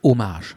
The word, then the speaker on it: Hommage: Das war richtig eine Hommage oder eine, sagt man Hommage oder Hommage? Das wäre sowas, was ich immer falsch ausgesprochen habe. – Ja, ist französisch, Hommage. – Genauso wie Refrain, wusste ich auch nie, Refrain. Ja, wie sagt man denn dazu? – Refrain. – Also habe ich auch lange falsch ausgesprochen. – Und Hommage, die Franzosen sprechen kein H aus.